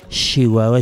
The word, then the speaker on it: Chihuahua: la ch se pronuncia sh por eso se dice Chihuahua, Chihuahua.